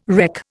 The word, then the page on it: wreck: Indian